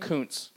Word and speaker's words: Kuntz: Tell me how you say yours. – Kuntz. – Kuntz, okay. – Yeah. It's spelled in a funky way, and the first day of school was a little tough for me always.